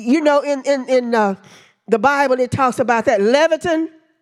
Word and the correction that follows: Leviathan: And his scales were so tight, they pronounce it Leviathan, that creature, they really don't know what it is.